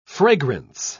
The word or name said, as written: fragrance